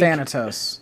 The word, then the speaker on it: Thanatos: Thanatos. That's how you say it.